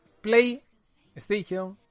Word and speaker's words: PlayStation: PlayStation, mejor pronunciado con la S, VR 2.